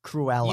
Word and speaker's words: Cruella: Cruella.